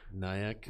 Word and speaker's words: Nayak: I won't, but according to local reports, Regipicable Nick. – Nayak. – Red Nayak.